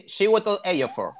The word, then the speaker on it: Chiwetel Ejiofor: Eh, de hecho, también esa película forma parte de Chiwetel Ejiofor, que no sé si ya se pronuncia así su, apellido. Sí, Chiwetel Ejiofor.